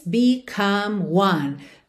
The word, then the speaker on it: become: Então, two by two, their bodies become one. Become one. Become one. Become one. Atenção que essas duas palavras, become e one, terminam em E, mas esse E não é pronunciado.